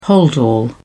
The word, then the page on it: holdall: British English